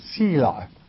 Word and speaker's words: Selah: How do you pronounce the word? It is Sheila.